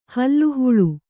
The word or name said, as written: ಹಾಲಹಲ